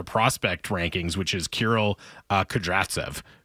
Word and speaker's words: Kudryavtsev: I've gotten in the habit of really pronouncing the first V in his name. – Okay. – Kudryavtsev.